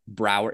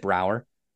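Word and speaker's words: Braugher: Andre Brower is how you pronounce it. Brower. Is it Brower? – Yeah.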